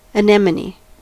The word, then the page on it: anemone: American English; /əˈnɛm.ə.ni/